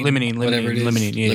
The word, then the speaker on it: limonene: I like the limonene too, or limonol, however you pronounce it. – Limonene or limonene? – Limonene. Yeah, limonene.